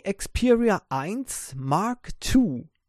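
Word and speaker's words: Xperia 1 II: Das Sony Xperia 1 Mark II, so wird das ausgesprochen, das wird geschrieben Sony Xperia 1 Römisch 2, okay?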